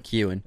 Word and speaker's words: Keoghan: I think that Barry Keoghan in this film, did I say it right? – Keoghan. I think that's right, Keoghan.